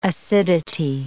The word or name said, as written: acidity